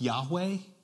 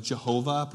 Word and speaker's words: Yahweh: So in your Bible, when you see where it says the Lord, all capital L-O-R-D, that's the God's name. And it's pronounced something like Yahweh. Or some traditions would pronounce it something like Jehovah. Probably Yahweh is probably a better way to pronounce that.